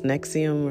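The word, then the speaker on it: NXIVM: The best Nexium one is on, and it's spelled like Nexvium, but it's Nexium or something.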